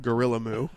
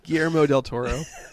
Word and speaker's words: Guillermo: Not Gorilla Moo. Not Gorilla. Guillermo del Toro. I can say his name perfectly fine.